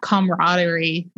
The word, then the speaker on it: camaraderie: You know, it's that camaraderie. I said that wrong, but. – No, you didn't.